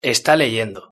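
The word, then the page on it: esta leyendo: Spain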